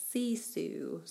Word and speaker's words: sisu: So now we move into part of the book where it talks about the word grit versus this Finnish word pronounced sisu, spelled S-I-S-U.